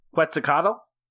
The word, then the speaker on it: Quetzalcoatl: Quetzalcoatl? – Yeah, you did good.